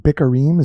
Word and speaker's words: Bikkurim: which that's pronounced Bikkurim, is that right? – Yes. – Okay,